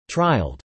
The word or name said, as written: trialled